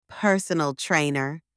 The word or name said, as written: personal trainer